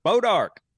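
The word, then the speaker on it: Bois d'Arc: Bodark. Yep. Well, that's not spelled anything like Bodark. – That's how it's pronounced.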